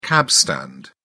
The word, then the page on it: cabstand: British English